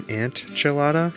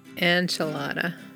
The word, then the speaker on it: enchilada: Pronounce enchilada. – You're pronouncing it like A-N-T, antchilada. It's an enchilada. – Enchilada. – You see, the computer said enchilada. – Enchilada. – But you call it an antchilada.